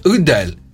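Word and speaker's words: Adele: Λοιπόν, είπε ότι κανονικά το Α δεν προφέρεται Α, αλλά προφέρεται Ο. – Από πότε. – Ο και το Ο πρέπει να είναι πάρα πολύ χαλαρό το Ο. Οπότε είναι Ούντελ.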